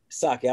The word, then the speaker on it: Psaki: Psaki, I always make a mistake in how to pronounce it. There's a P at the beginning, I think.